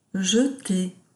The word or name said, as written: jeter